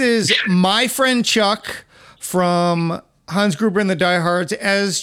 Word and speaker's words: Hans: I'm pretty sure it's Hans. You got to get that long A in there.